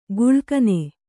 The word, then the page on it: ಗುಳ್ಕನೆ: guḷkane